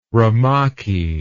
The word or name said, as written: rumaki